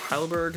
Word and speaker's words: Heidelberg: Facebook name is Jeff Heidelberg. That's spelled H-E-I-D-E-L-B-E-R-G.